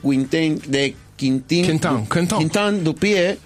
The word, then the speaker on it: Quentin Dupieux: La primera de ellas es de Quentin Dupieux, así se pronuncia.